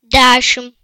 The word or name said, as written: 10